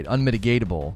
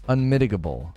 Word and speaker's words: unmitigable: Unmitigatable. Unmitigable. It's unmitigable. It's unmitigable. There's no T. It's not unmitigatable. It's unmitigable.